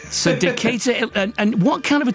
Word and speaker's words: Decatur: You grew up in a place, is it Decatur? Am I pronouncing that correctly? – Decatur. – Decatur. Well, I've been pronouncing that wrongly for all my life.